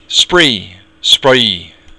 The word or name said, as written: spraoi